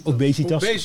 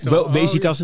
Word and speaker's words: obesitas: En, er is in, in Suriname heel veel um, osbenitas. Zeg ik het goed? Obesitas. – Obesitas.